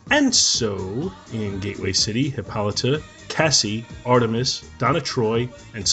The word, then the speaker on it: Hippolyta: Is it Hippolyta or Hippolyta? How's it pronounced? Do you guys know? – Hippolyta. – Hippolyta. Thank you.